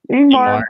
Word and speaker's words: Ingmar: As far as black and white cinematography goes, I thought this was like up there with like the Ingram. I said his name wrong already. – Ingmar Bergman.